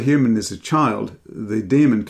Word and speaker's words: daemon: We're introduced to Lyra Balacqua, a young girl who's accompanied by her demon, Pantalaimon, a small furry animal. What is a demon? This is spelled D-A-E, by the way, or a diphthong, daemon, demon.